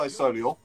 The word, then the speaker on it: Solihull: I don't even pronounce the H. I say Solihull.